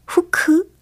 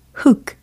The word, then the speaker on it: hook: 이 중에서 이 후크, 후크라고 많이들 말씀을 해보셨을 텐데, 영어 단어 H-O-O-K 이거잖아요. 그죠? H-O-O-K 발음을 영어식으로 정확히 한번 해보세요. 훅 아니고, 후크 아니고, 바로 훅 이겁니다. 훅 들어오는 거 아니고요. 후크 아니고요. 훅 이렇게 하신다고요. 끝부분에 크, 크, 크 이렇게 끝난다는 거.